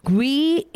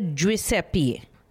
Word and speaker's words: Giuseppe: For example, Giuseppe, it's not Gui, it's rather Giuseppe.